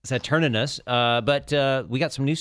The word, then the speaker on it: Saturninus: and there is a second N in there. I had to double check it. So it's like Saturninus.